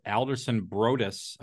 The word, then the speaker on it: Alderson Broaddus: You actually started your athletic career at Alderson Broaddus, I believe is how you pronounce it, right? – Yep.